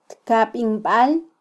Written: tkabinbal

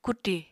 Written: kuti

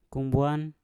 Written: kungwan

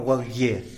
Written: walgie

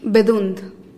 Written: bedund

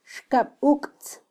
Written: xkabuktz

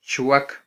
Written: chwak

